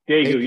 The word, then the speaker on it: Daegu: The Black Traveler's Guide to, am I saying this right? Is it Daegu? – Yeah. Daegu, yes.